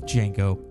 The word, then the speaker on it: Django: Especially along the lines of Django Unchained, The D is Silent.